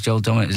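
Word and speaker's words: Joel Dommett: Is that right? Yeah. Oh, no, okay, it's Joel Domet.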